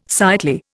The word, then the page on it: sightly: Indian